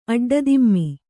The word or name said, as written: ಅಡ್ಡದಿಮ್ಮಿ